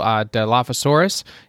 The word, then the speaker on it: Dilophosaurus: There's a purple Dilophosaurus.